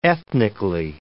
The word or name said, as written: ethnically